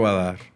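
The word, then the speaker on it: Vadhar: Pedro Badar.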